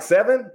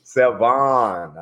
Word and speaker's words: Sevan: How you say your name? Like Seven? Like Lucky Seven? – Like Lucky Seven, but Sevan. – Sevan, I like it.